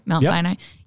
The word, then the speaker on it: Mount Sinai: But then even thinking back over us not being the people who walk up the mountain, who walk up Mount Sinai, and did I say that right? Mount Sinai? Yeah, who walk up and approach God.